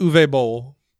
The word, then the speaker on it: Uwe Boll: Let's start off the show by talking about Uwe Boll. That's how you pronounce his name.